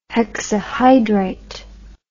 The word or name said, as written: hexahydrite